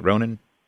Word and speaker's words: Ronin: Eh, did I am I pronouncing that right? Ronin. Uh, yeah, we would say Ronin.